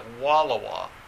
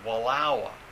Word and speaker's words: Wallowa: It looks like Wallowa, but it's pronounced Wallowa.